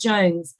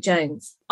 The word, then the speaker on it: Jonze: I think it's pronounced Jones because I love Jackass and both of them do Jackass. – What? J-O-N-G-Z-E? – Yeah, Jones. Spike Jones. I'll take the flack if it's not Jones.